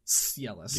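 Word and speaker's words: Psellis: First up, we have something called the Pacellus. Or just the Cellus, maybe? – I think it might be Cellus. – It's got a P at the front, which... – I think it's a silent P.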